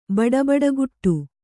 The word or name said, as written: ಬಡಬಡಗುಟ್ಟು